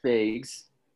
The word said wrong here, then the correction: Fage: I don't know if I'm pronouncing the last name right. – I think it's Fage, like yogurt.